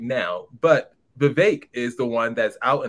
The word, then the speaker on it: Vivek: But afterwards, although Vivek, that's what he calls it, Vivek rhymes with cake. That's how you kind of should know how to say his name.